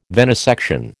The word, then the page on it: venisection: American English